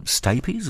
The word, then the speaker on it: Stapes: and this is called Stapes. Is that the right pronunciation for it? – That's correct, yeah.